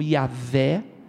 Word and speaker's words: Yahvé: É o, o nome que em hebraico se pronuncia como Yahvé, e a Septuaginta, a tradução para o grego, traduziu como egoemi.